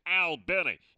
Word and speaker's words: Albany: Yeah, it's spelled just like Albany, but like we told you, it's pronounced Albany.